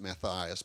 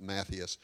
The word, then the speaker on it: Matthias: He says in verse 26, then they cast lots, and the lot fell to Matthias, some pronounce it Matthias, but Matthias.